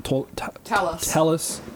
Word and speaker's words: Talliss: Talliss. It's like Tegan, like the A is pronounced the same.